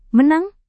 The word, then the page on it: menang: Indonesian